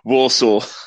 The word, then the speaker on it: Walsall: But anyway, just briefly, because we're not going to have a Walsar. I can't pronounce it. You pronounce it for me, please. Oh my God. – Walsall. – There we go.